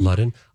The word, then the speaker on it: Ludden: Ludden.